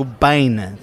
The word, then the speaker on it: Biehn: Let's say his name correctly. Not Michael Biehn. He's not, Mr. Bean's cousin. He is Michael Biehn.